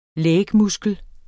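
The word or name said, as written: LÆGMUSKLER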